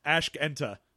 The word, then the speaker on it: Ashkente: Because I'm sure I'm pronouncing that wrong. I've always felt like there's probably a joke in that word that I'm not seeing. – It's Ashkenta. – Ashkenta? – Yeah, for Ask Enter.